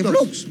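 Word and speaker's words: Blockx: Porque se escribe B-L-O, B larga, ¿no? Sí. B alta, L-O-C-K-X. – Z. – No. No, ¿eh? Se cierra con X. – Entonces Blocks nomás. – Es Blocks, claro.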